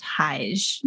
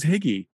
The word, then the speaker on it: hygge: So, I think it's pronounced Hige. – Hige? I thought it was Higgy.